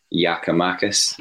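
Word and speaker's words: Giakoumakis: Yakamakis, is that the right pronunciation? – Apparently um, so.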